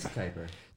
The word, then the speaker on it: Kiper: It says Chuck Kiper, and in parentheses, pronounced like Viper. – Look at this. – But spelled with a K.